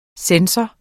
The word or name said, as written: sensor